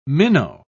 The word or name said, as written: minnow